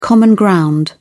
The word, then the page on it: common ground: British English